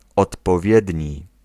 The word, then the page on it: suitable: [ˈsuː.təbl]